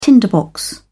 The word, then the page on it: tinderbox: British English